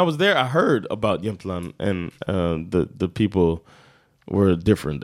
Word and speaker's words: Jämtland: And it's called Jämtland locally. That's how they say it, Jämtland.